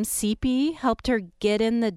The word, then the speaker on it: Cipe: Yeah, CP is spelled C-I-P-E, but pronounced like the letter C-P, which is also her initials.